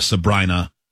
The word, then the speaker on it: Sabrina: I think it's Sabrina, though, because I remember reading somebody said that the pop singer rhymes with vagina. Sabrina.